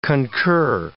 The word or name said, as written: concur